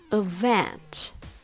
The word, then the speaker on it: event: Even though even has the stress on the first syllable, event has the stress on the second syllable.